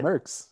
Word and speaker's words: Merckx: The final topic, is Eddie Merckx. If I'm pronouncing that right. Am I pronouncing that right? – Merckx. Yeah.